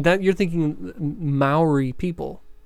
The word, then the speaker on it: Maori: I think it's Maori. It's M-A-O. – Maori. It's pronounced Maori. – You don't know that. – I do. M-A-O-R-I.